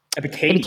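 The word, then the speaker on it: Ebiketie: Ebikade? I'm saying his name right? Ebikade? Ebikade? – Ebikade.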